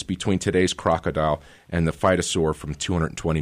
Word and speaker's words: phytosaur: Yeah, and 220 million years ago, you have this thing called, I believe you pronounce it, a a phytosaur? – That's correct, yeah.